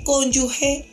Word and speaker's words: cónyuge: Considero propicio indicarle que la palabra cónyuge se pronuncia como les estoy diciendo, he, y no cónyuge. Muchas personas la pronuncian en forma errónea y en la última sílaba la pronuncian como si tuviese una U. Entre G y E existiera una U y no es así.